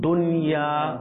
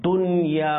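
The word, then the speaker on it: দুনিয়া (دنيا): যেমন এটার উচ্চারণ কিভাবে করতে হবে, দুনিয়া অর্থাৎ দুন গুণনা করাটা এখানে হচ্ছে কি ভুল, এখানে হচ্ছে কি ভুল, এটা সঠিক উচ্চারণ যেটা সেটা হচ্ছে কি দুনিয়া, দুনিয়া, ঠিক আছে, বোঝা আসছে আলহামদুলিল্লাহ। মাদানী চ্যানেলের দর্শক শ্রোতা, যে আশা করি আপনাদেরও বোঝা এসেছে যে এখানে দুন গুণনা হবে না,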